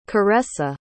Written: caressa

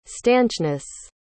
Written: stanchness

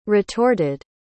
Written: retorted

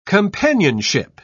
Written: companionship

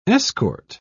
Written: escort

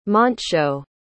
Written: Montsho

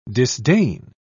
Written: disdain

